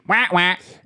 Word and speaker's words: Wawa: If we're gonna stop there, you better pronounce it, wah, wah. Don't make any chance unless it's wah, wah. – Maybe they could be another sponsor, corporate sponsor. – Oh, man, Wawa.